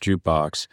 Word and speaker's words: JKBX: So fractional ownership, this company, JKBX, pronounced Jukebox, is aiming to democratize music investing.